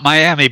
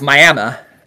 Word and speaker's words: Miami: M-I-A-M-I. How would you pronounce that? – Guess what? It's not Miami, baby. – Nope. I mean, it's close. It's Miami, but yeah.